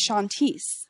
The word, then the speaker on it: Chantise: My real name, my birth name is Chantise, but in French it's Chanteuse, which means singer.